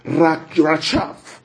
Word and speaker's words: rachaph: Rakab, I'll spell it for us. R-A-C-H-A-P-H, Rachav, really. Yeah, R-A-C-H-A-P-H. Now, it's a word that implies incubation. Go back again. It's a word that implies incubation. Yeah? Rachav. It's a word that implies to brood. B-R-O-O-D.